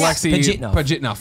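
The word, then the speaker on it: Alexey Pajitnov: Yes, Alexei Pajitnov.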